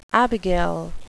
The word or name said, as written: abigail